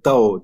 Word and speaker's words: Dao: Dao diye telaffuz ediliyor.